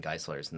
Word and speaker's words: Geyselaers: you know, actually it's pronounced Geyselaers. – Wow. – I'm like, I'm sorry, how's it pronounced? Geyselaers? What? Which one? Because there are plenty.